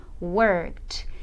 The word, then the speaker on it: worked: Então, work com o ED, eu vou dizer worked. Ele vai sair com o som de T.